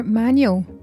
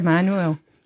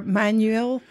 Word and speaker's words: Manuel: Peter Manuel. How do you pronounce it? Manuel or Manuel? – Well, there's one very irritating commentator who calls him Manuel, as if he's out of Fawlty Towers.